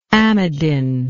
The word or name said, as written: amidin